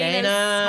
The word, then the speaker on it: Danai: Technically D-A-N-A-I, but she likes to pronounce it D-A-Y-N-A.